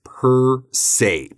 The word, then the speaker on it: per se: So the phrase we're talking about here is per se, P E R S E.